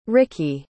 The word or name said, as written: ricki